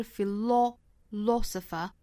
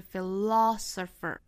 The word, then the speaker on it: philosopher: 我们看到这个第一个字母 O 对应的元音发音应该是 a。我们刚才说到英音是 philosopher，美音的话注意是 philosopher。